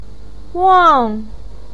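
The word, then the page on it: wang: tone 4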